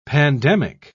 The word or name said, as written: pandemic